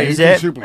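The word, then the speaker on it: Ethan Suplee: Yeah, Ethan Supley.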